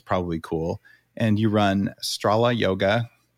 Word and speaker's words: Stråla: she's pronounced it Strola.